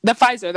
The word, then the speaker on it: Pfizer: Cause like I got the, how do you pronounce it? The PF, Pfizer. the Pfizer.